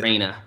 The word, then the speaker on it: ocarina: Ocarina. Reina, like it's raining outside.